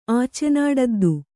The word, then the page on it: ಆಚೆನಾಡದ್ದು: ācenāḍaddu